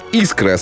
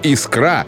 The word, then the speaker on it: искра: Согласно орфоэпической норме русского языка, ударение в слове «искра» следует ставить на гласную букву «и» первого слога. Вариант с ударным вторым слогом «искра» является жаргонным и в литературной речи не должен употребляться.